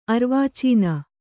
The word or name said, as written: ಅರ್ವಾಚೀನ